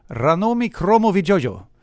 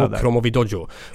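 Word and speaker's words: Ranomi Kromowidjojo: Ranomi Kromovidjojo. Ranomi Kromovidjojo. Det kan du köra snabbt där din uttalsskola. – Ranomi Kromovidjojo.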